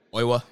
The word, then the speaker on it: Oiwa: Fujita and, how do you say his last name again? Oiwa.